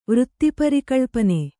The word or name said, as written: ವೃತ್ತಿಪರಿಕಳ್ಪನೆ